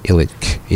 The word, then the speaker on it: Ilic: then ILIC, Illich, is my surname.